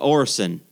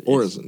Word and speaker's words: orison: How did you pronounce it? I know what it is, but I don't know how to pronounce it. – Orison. – At first I thought it was Orison, but... – Orison. Yeah, it's Orison. – O-R-I-S-O-N, and that is a synonym for prayer.